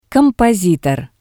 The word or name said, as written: kompositor